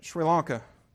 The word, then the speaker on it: Sri Lanka: And I'm sending this brother and his wife to Sri Lanka.